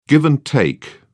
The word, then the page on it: give-and-take: British English